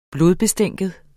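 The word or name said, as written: blodbestænkt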